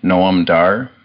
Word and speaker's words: Noam Dar: Noam Dar? – Yeah.